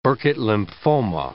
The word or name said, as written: Burkitt lymphoma